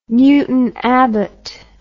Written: Newton Abbot

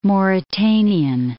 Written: Mauritanian

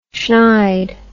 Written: shnide

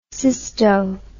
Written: cysto

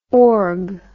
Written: org

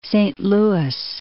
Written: Saint Louis

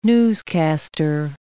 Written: newscaster

